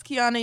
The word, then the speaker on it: Kiana: Y'all already know I am Rebellious Kiana.